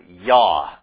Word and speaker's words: Yah: But the first syllable of what I do believe is a two-syllable word is without question. It ends names that the name of God is in, like Isaiah, and Jeremiah, and Zechariah, you know, Yah, that shortened form, the abbreviation of Yahweh,